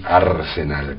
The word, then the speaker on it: Arsenal: Sí, Arsenal, con acento en la A inicial, Arsenal, o sea, por el Arsenal de Londres, no por Arsenal de Sarandí o Arsenal de Sarandi, como prefieran, ¿no?